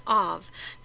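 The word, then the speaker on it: av (אב): Now, Aleph Beit also has come to mean the term father, pronounced av. The B is pronounced like a V in this word.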